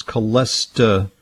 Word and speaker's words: Cholestapure: Okay, good question, because Cholestapure is a kind of a unique product that in contains, it's spelled like cholesterol, but it's Cholestapure.